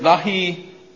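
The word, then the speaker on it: Dahimanion (δαιμόνιον): It's the Greek word, dahimanion. Dahimanion. Did I say that right, Ed? – Yeah. – Dahimanion.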